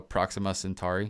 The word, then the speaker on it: Proxima Centauri: So Proxima Centauri, am I saying that right? – Yeah.